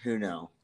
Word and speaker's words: Hunou: Adrian Huno. – Yeah, Adrian Puno. – I said Huno. – Huno, yeah. – Even I actually don't know how to say his name. It's Hunou, I want to say.